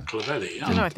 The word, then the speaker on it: Clovelly: Clovelly, yeah.